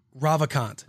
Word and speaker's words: Ravikant: Yes. Say that. – Ravikant? – Ravikant. – That's how I'd say it.